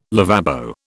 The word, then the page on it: lavabo: British English